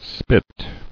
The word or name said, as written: spit